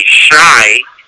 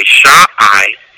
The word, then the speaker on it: Shai: Well, Shai, that has two pronunciations. If you say Shai, which is also spelled S-H-A-I, you're talking about the ancient Kemetic god of destiny. Mm. However, when you say Sha'ai, you break it down into two syllables, and you put a little emphasis on the I, it just means destiny.